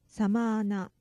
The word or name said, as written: sama ana